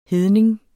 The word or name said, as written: hedning